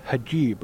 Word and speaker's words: hijab: And now, uh, I, I figured you were Muslim because you have the it's a hijab, right? – Hijab.